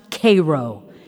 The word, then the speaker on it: Cairo: Cairo, Illinois, he said, explaining that though it was spelled like the city in Egypt, it was pronounced Cairo, the Illinois way.